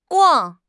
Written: guang4

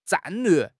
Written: 战略

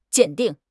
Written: 鉴定

